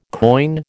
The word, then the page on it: coin: American English